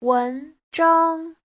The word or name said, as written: wen2zhang1